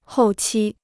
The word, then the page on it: 后期: hòu qī